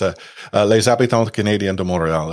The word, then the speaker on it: Les Habitants Canadiens de Montréal: It's Les Habitants Canadiens de Montréal, essentially the Montreal Canadiens.